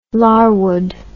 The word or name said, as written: Larwood